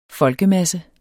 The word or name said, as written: folkemasse